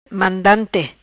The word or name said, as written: Mandante